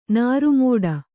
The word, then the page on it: ನಾರುಮೋಡ: nāru mōḍa